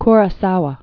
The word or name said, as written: Akira Kurosawa